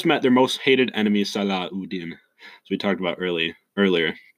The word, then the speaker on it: Salahuddin: the most powerful groups in Latin Europe go against the Salahuddin, which is pronunciation, the original pronunciation.